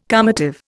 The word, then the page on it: calmative: Indian